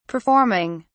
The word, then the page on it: performing: British English